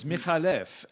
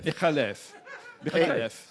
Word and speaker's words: McAuliffe: McAuliffe is actually an Arabic name, and it's Michalef, and the C is just for spelling pronunciation. – Right, Michalef. – Michalef.